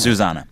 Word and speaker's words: Zuzanna: Su- okay, You're saying Susanna.